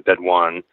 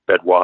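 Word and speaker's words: Bédoin: Carome is a little town that's about halfway in between Malassine and Bedouin, or Bedouin, I think the French pronounce it, which are two of the starting points of kind of the two major climbs up to the top of Ventoux.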